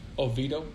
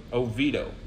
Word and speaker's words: Oviedo: In how do you say that city name? – Oviedo. – Oviedo. I add extra vowels for some reason.